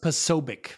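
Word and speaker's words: Posobiec: According to Wikipedia, it's pronounced Pasobic.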